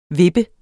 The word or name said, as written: vippe